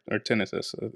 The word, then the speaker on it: tinnitus: And that's a reference to, you know, I actually have pretty severe tinnitus or tinnitus is how it's pronounced, which is a persistent ringing in your ears.